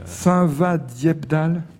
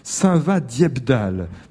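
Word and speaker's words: Saint-Vaast-Dieppedalle: Donc, Saint-Va-Dieppe-Dalle. – C'est ça, exactement, bien prononcé. – Je me suis entraîné avant, vous m'aviez soufflé la prononciation du nom de votre commune à l'oreille avant l'émission. Saint-Va, ça s'écrit comme Saint-Va-la-Hougue, hein, qu'on connaît bien dans le Cotentin aussi, c'est V2AST. Saint-Va-Dieppe-Dalle, parlez-moi de votre métier d'agriculteur, que cultivez-vous ?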